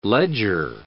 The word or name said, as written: ledger